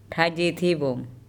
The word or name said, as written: Thaijethebom